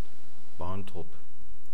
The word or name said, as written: Barntrup